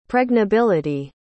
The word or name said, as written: pregnability